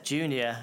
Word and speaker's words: JEWKNEOR: Okay, so my Instagram and Twitter handle is phonetically said Junior, and it is spelt JEWKNEOR, or if you're a member of NATO, Juliet, Echo, Whiskey, Kilo, November, Echo, Oscar, Romeo, or if you want to break it down phonetically, it would be... Jew, like the follower of the Jewish religion. Knee, like the bend in your leg. Or, as in either or.